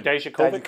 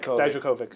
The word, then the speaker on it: Dijakovic: Dijakovic. – Dijakovic.